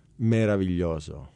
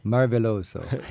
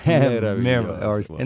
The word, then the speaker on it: meraviglioso: Meraviglioso. – Marveloso. – Meraviglioso.